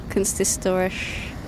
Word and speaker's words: Kunsthistorisch: Kunsthistorisch.